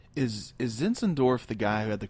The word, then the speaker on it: Zinzendorf: Zinzendorf.